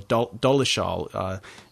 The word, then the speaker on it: Dolezal: My last name is Dolezal or Dolezal, is probably a more correct pronunciation.